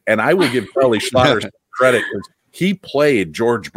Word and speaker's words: Schlatter: With George Burns and Charlie Schlatter.